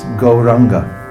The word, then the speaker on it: Gauranga: It's four syllables, but it's pronounced Gauranga.